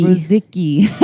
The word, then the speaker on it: Ruzicki: Ruzicki. He says it rhymes with... Yeah, sticky.